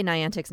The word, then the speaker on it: Niantic: Did I say Niantic's name wrong? – You said it right.